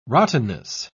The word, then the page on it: rottenness: British English